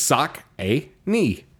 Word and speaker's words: Saucony: I know it's sock a knee.